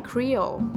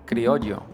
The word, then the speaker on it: criollo: How do you say Creole? – Creole? Criollo. – Yeah. – Seriously? – Yeah. – I was going to say creole, but it's not. – No, criollo.